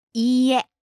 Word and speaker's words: いいえ: いいえ. – Say it one more time. – いいえ. – All right, so the accent, it goes up on the second syllable.